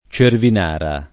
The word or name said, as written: Cervinara